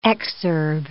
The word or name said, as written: exurb